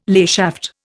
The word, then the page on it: layshaft: Indian